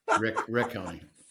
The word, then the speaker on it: Riccone: Hey, if it makes you feel any better, Rich sometimes still spells Dan and Mai's last name R-I-C-C-O-N. – Riccone.